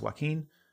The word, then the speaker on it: Joaquin: Jarquin Phoenix, that's not his name, it's Joaquin, all right, like...